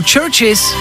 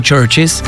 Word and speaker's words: CHVRCHES: Tohle jsou churches, když to budete hledat, je to jako víc kostelů, ale místo U je V, takže ch, v, r, ch, s, chvrches, chvrches se to píše, chvrches, ale čte se to churches.